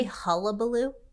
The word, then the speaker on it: hullabaloo: It's Did you say hullabaloo? – Yeah. Is that what I said? I didn't say it properly. I said hubbubaloo. Is it hullabaloo? – Hullabaloo. Wait. – Hullabaloo. I said hubbubaloo. – I think it's hullabaloo, right? – Okay, whatever.